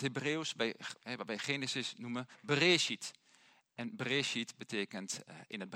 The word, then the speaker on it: Bereshit: Maar Bereshit spreek je het uit.